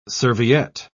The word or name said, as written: serviette